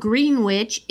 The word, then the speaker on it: Greenwich: Even though it's spelled Greenwich, it's pronounced Greenwich, correct? – Mm-hmm.